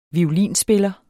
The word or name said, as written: violinspiller